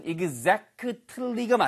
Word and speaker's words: exactly: 발음 하나만 주의하세요. exactly라고 안 들리고요. exact 한 다음에 그 으 소리 때문에 트 발음이 잘 안 들려요. 그래서 사실은 exactly가 맞지만 들리는 발음은 exactly. 마치 끌리가 어디서 오게? 이런 식의 느낌처럼 실제로 원어민들이 이렇게 발음을 해요.